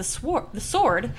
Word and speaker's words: sword: And also, Sienna notices that the sword, I wanted to pronounce the W in sword.